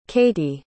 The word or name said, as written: caitie